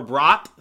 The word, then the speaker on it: Bratt: So, I personally prefer Jesper Bratt, so like bratwurst, like the German sausage.